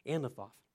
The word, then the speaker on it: Anathoth: It's not a speech impediment. It's the way the word, I looked it up. It is the correct pronunciation, Anathoth.